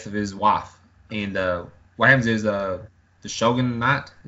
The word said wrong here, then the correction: Shogunate: is that how you say it? – Shogunate.